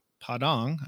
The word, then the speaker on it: Padang: But, you know, one of the things that I also remember about you is we took this trip to Padang.